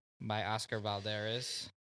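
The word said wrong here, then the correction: Valladares: It's Valadares. – Valadares. What? – You said Valderez.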